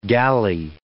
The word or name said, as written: galley